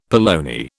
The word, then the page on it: polony: British English